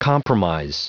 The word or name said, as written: compromise